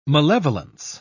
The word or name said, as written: malevolence